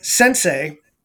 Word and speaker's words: SEN-SEI: Now, the hyphen is interesting because I also took martial arts when I was very young, and my sensei, when they read my name and said, yeah, you have, your name is pronounced the same way, this is what it means.